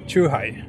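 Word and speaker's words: Chuhai (チューハイ): Hur uttalas det? Är det Chuhai? – Chuhai. – Chuhai, okej. Det låter roligare med Chuhai. – Chuhai. Det är liksom Chuhai på bred skånska.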